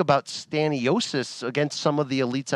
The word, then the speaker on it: Stanionis: It was Aimantas Stanionis. Is that how you say his name? – Stanionis. I'm sorry that I was butchering it at times, even though he's my Lithuanian brethren.